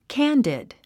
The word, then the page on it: candid: kǽndid; キャンディド